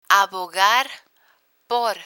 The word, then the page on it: abogar por: Spanish